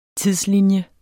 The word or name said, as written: tidslinje